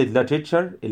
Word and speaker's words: literature: literature الأدب العالمي. فاكرين literature خلاص كده قلنا التي الأخيرة دي اللي بعدها اليو كأنها بتتنطق شين لاتريتشر.